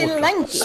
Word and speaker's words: Spelunky: Splanky, eller hur uttalar du egentligen? Jag, aldrig, jag svårt Spelunky. Spelunky. Spelunky.